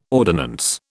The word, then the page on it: ordinance: British English